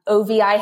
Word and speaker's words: OVI: And then OVI Healthcare it's on LinkedIn, it's on Instagram, it's on Facebook. Venmo is OVI Healthcare. It's just all OVI Healthcare. And then pronounced OV, but spelled OVI.